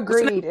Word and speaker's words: Grede: Emma Greed? – Yeah. – Like money? – Like her last name is spelled G-R-E-D-E. – Oh, G-R-E-D. Okay. I thought you meant like G-R-E-E-D. I was like, well, that's a great stage name.